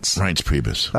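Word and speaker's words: Reince Priebus: Rince Priebus.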